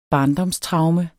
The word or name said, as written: barndomstraume